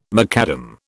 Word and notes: macadam: British English

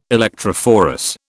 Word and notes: electrophorus: British English

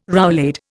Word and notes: roulade: Indian

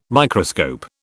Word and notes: Microscope: British English

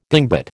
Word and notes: dingbat: American English

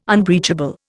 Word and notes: unbreachable: Indian

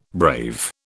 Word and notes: brave: British English

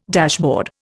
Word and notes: dashboard: Indian

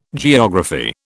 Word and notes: geography: British English

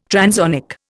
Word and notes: transonic: Indian

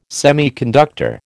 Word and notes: semiconductor: American English

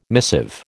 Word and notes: missive: American English

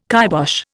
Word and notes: kybosh: Indian

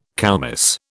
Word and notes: koumiss: British English

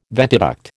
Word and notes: ventiduct: American English

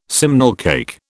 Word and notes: simnel cake: British English

